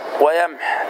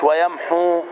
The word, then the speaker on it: ويمح: فأقف وهكذا ويمح، لماذا؟ لأنها وردت محذوفة الواو مش ويمحو، وقد ورد حذفها في 5 مواضع وحذفها لفظا ورسما ويوقف على الحرف الأخير. لفظا يعني لا تقل ويمحو، لا تتلفظ بالواو، ورسما يعني الحرف الأخير في الكلمة حاء خلاص وليس واو، يعني ويمح عند الوقف عليه للاختبار أو للتعليم أو للاضطرار.